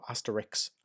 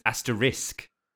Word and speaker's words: asterisk: So is it asterisk or asterix? Asterix, I would say, but. – Asterix. Yeah, I think it's, but it's asterisk. It's spelt A-S-T-E-R-I-S-K. – Oh, okay. – Asterix is the comic.